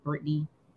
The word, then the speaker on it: Britne: But you guys can catch me on Instagram, TikTok, and Twitter at Britne Monique, right there on my screen at Britne Monique. No Y. I don't have a Y in my name. So that's how you spell it.